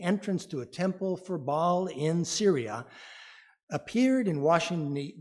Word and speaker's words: Baal: You may have heard of the Arch of Baal, or Baal as it's sometimes pronounced.